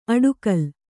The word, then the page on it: ಅಡುಗಲ: aḍugala